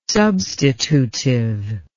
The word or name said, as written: substitutive